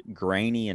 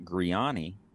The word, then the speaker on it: Grainy: And, but I did learn why it's pronounced Grainy and not Griani, as our name is spelled. The Germans pronounce the second vowel when there's back-to-back vowels in a name. And being that we came off the boat from Germany, it's Grainy, pronouncing the A, not the E, so not Griani.